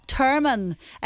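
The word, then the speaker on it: Termon: And Sean again in St Johnson, and you're saying it is Termin, the place in Donegal. It's to rhyme with Herman.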